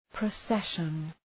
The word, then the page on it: procession: prə'seʃən